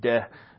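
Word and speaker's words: de (δέ): That Greek particle, de it is de, is how it's pronounced, is but avoid worldly and empty chatter, which will lead to further ungodliness.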